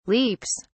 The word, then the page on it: leaps: British English